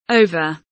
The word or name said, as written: over